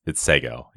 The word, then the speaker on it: Seg-El: I saw a bunch of interviews with the correct pronunciation. It's Seg-El.